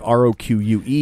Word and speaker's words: roque: And it even tells you how to pronounce it because I would have pronounced it like roque. I think it's like R O Q U E. – Yeah, but this isn't croquet. It's roque.